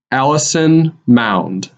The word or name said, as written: Allison Mound